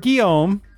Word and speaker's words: Guillaume: Robert Guillaume, right? – Yes, yeah.